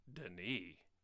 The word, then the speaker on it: Denis: Oh, Denis. – I believe that is the correct pronunciation. – It is.